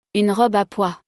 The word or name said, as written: Une robe a pois